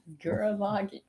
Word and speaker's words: Gurren Lagann: Gurren Lagann.